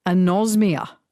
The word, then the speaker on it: anosmia: Now, apparently this is called anosmia, the loss of smell. Is that right, how you say it? – That's exactly right.